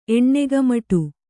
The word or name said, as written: ಎಣ್ಣೆಗಮಟು